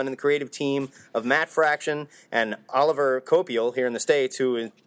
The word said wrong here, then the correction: Olivier Coipel: Olivier Coipel.